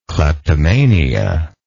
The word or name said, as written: cleptomania